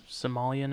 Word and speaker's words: Simalia: Yep, it's Somalia now, I think is how you pronounce it. S-I-M-A-L-I-A. – Yeah, I've seen that. – A-L-I-A.